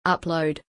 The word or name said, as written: upload